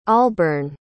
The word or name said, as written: alburn